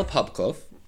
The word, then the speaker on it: Popkov: Anyways, Mikhail Popkov, whose last name kind of sounds like popcorn,